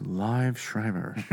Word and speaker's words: Liev Schreiber: It's pronounced Liev Schreiber.